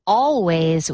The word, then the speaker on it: Alvvays: That was Always with a beautiful song called In Undertown on XX1051. And here's the thing about the band. Their name is Always, but it's spelled A-L-V-V-A-Y-S.